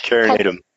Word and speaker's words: carinatum: You can treat this with a Nuspar, and then the pectus carnitatum. Pectus carnitum. – Carnitum. – How do you say it? – Carinatum.